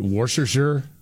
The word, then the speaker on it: Worcestershire: Well, I heard it Worcestershire, like that's how you're supposed to pronounce it.